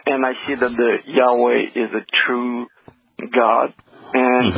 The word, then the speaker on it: Yahweh: The most accurate pronunciation that we can have of Yod He Wah He is Yahweh. The Y is pronounced just as it is in English and in most languages. The two Hays are pronounced Ah. It's a vowel in Hebrew, and the W or Wa in Hebrew is pronounced like the English O. So it'd be Yahweh.